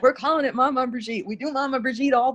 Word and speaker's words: Maman Brigitte: Maman Brigitte.